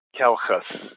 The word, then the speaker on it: Calchas: The consonant C is always hard, like a K, and a CH sound is generally pronounced as a K also, but I would urge you to say it with a little bit of aspiration, as though you're clearing your throat, H. So, for example, calchas is maybe a little different than calcus.